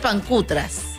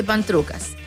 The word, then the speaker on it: pancutras: Se pronuncia pancutras. – ¿Ya? – Ya, pero la gente dice pantrucas.